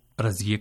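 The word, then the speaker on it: رضیه: سامعین, آپ نے یہاں جو جملے سنے ان میں خواتین کے جو نام ہیں رضیہ ناصرہ اور نسیمہ، ان کا فارسی تلفظ آپ نے ملاحظہ کیا کہ رضیہ کو رضیے کہا گیا، ناصرہ کو ناصرے کہا گیا اور نسیمہ کو نسیمے۔ ہم بتا چکے ہیں کہ یہ ہائے غیر ملفوظ کہلاتی ہے، اور اس طرح کے سبھی ناموں کو آپ اردو کی بڑی یہ کی طرح تلفظ کیجیے۔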